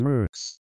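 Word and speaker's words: Merckx: It is pronounced Mercix. Eddie Mercix. Rhymes with jerks.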